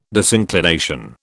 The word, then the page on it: disinclination: British English